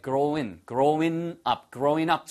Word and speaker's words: growing up: 예, 발음은 growing 하셔도 되고요. 예, growing up 처럼 응 소리가 은처럼 들리기도 합니다.